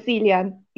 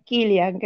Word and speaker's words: Cillian: O, Killian, Killian Murphy, que se escribe Cillian y yo a veces le digo el Cillian y es Killian, creo.